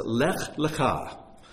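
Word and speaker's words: Lech Lecha (לך לך): God speaks to Abraham, and he says, Lech Lecha. Now, this is Lech, this is Lecha. I know that in the Torah scroll, they're spelled exactly the same, but the two words are pronounced differently.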